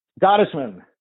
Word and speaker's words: Gottesman: Gottesman.